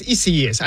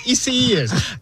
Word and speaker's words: Isaias: It's Isaias or Isaias. No, it's Isaias. I just heard it. It's Isaias.